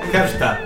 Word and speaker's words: Hrsta: Hersta. Se escribe H-R-S-T-A.